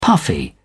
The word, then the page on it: puffy: British English